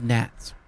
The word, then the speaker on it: gnats: It was called because of gnats. – Gnats. It's funny, I was going to say bats, but which rhymes with gnats.